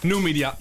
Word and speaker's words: Numidia: Numidia. Dan moet ik het goed uitspreken. Klopt het? Heb ik het goed gezegd? – Numidia. – Numidia. – Ja, Numidia. Yes. Oké, mooi, gelukkig.